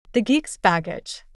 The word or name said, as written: The geek's baggage